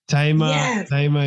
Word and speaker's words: Thema: the H is what throws people off because the H is silent. So I usually get the Thema, or some people think I left the L out and they'll say Thelma.